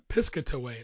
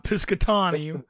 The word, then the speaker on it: Piscataway: So I believe it's pronounced Piscataway. It's P-I-S-C-A-T-A-W-A-Y. Piscataway. Not Piscatawny. It's not that. It's Piscataway.